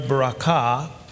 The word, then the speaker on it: barakah: Now that blessing comes from the word barakah. The accent is on the last syllable.